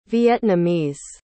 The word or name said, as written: Vietnamese